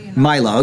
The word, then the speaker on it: Milo: From Milo, is that how you say that guy's name, that conservative guy, Milo or Milo? – Milo, okay, all right, I can't pronounce that last one.